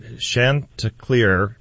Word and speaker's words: chanticleer: The next one we have is that my husband is trying to pull the stump of a chanticleer pear tree out. So I'm sorry if I'm pronouncing that wrong, Sandy. – That sounds good.